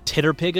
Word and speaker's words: TTRPG: And then my other, this is the one I'm mostly active in, is called the Titterpig Academy. Titterpig, because if you take TTRPG and you phonetically sound it out, it's Titterpig.